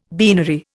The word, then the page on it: beanery: Indian